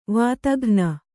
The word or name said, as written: ವಾತಘ್ನಿ